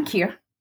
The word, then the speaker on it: Kir: K-I-R. – K-I-R. Am I saying it right? – Mm-hmm, Kia. Mm-hmm.